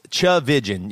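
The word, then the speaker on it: Tchividjian: It's pronounced Chavijan. Yeah. – Chavijan is how the phonetics of it are.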